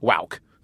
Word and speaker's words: WOWK: Was that generally pronounced WOWOK or WOWK? – WOWK. WOWK TV.